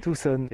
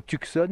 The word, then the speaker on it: Tucson: Ah, tout le monde dit ça, mais le C, c'est silencieux. Alors, s'il vous plaît, quand vous venez ici, il faut prononcer Tucson. – Tucson.